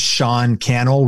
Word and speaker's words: Sean Cannell: And my name is Sean Cannell, rhymes with YouTube channel. And S-E-A-N-C-A-N-N-E-L-L.